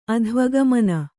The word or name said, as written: ಅಧ್ವಗಮನ